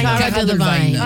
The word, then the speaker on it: Cara Delevingne: Cara Delevingne.